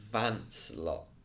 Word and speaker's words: Vincelot: I've got a feeling it's Vancelot. It's definitely low as in the silent T, but I think it's Vance. I think I've been told. We'll get that clarified. – It is French. It's a French name.